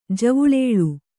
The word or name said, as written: ಜವುಳೇಳು